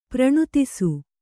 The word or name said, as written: ಪ್ರಣುತಿಸು